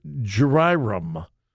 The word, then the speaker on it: Jayaram: Christopher Jayaram, J A Y A R A M, you pronounce it, okay?